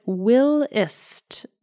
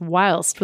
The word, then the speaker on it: whilst: You talked in an early episode about liking British speech, including a word that sounded like will-ist with a short I. I thought from context the word was whilst, which in British and Australian English is pronounced whilst with a long I.